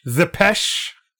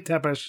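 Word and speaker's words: Țepeș: Zepesh. Is the proper. Yeah, they say Tepish in the show, but it should be pronounced Zepesh.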